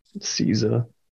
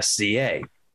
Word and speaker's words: SZA: SZA. – Yeah, so I just said SCA because that's what it's written out as. Never even thought about it before. And then that person was like, you mean SZA?